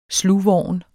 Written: SLUGVORNT

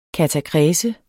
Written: katakrese

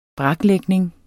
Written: braklægning

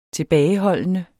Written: tilbageholdende

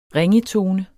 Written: ringetone

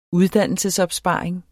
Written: uddannelsesopsparing

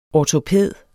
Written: ortopæd

